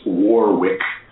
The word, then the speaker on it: Warwick: I think it would be pronounced in the States Warwick, like the city in Rhode Island.